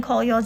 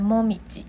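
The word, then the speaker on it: 紅葉: 然后另外还有一个意思， koyo 它也红叶这两个字念，除了念 koyo 之外，它也可以念成 momiji。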